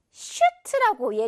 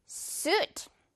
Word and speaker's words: suit: 자, 여기서도 이거 한국인들이 슈트라고 얘기할 때 많은 것 같은데 이 지금 이 모음은 우 소리예요. 그래서 suit.